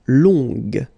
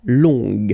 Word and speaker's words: long: Okay, so long, remember you don't pronounce the final G, will become long. Okay, so phonetically it's long. G-g-g-g-g, long.